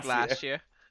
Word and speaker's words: Glacier: No, say it like an English person. Glacier.